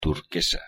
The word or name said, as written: turquesa